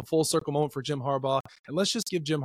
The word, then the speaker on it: Harbaugh: Jim Harbaugh to the Los Angeles Chargers. I said it right. Jim Harbaugh to Los Angeles Chargers.